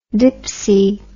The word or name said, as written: dipsey